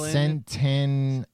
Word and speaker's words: centenarian: Centaurian? – No. – Centennial? – Centenarian. – Centenarian.